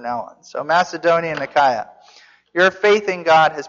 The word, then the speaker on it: Achaia: So it's a diphthong there, alpha iota, which is an I, and then there's a little subscript to iota, which would be an a. So as far as I'm concerned, it's Achaia. So from now on,